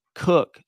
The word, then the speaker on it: Koch: Cook, that's how his family pronounces their name, K-O-C-H, but pronounced Cook.